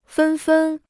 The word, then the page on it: 纷纷: fēn fēn